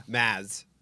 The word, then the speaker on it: Mads: Mads.